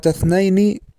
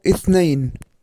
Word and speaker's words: اثنين: والخطأ الآخر الملاحظ في همزة الوصل في ضبطها بالشكل حين البدء بها، بعض الإذاعات العربية نطقت كلمة اثنين بفتح الهمزة، والصواب اثنين.